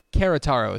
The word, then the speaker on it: Querétaro: Querétaro is probably how it's pronounced.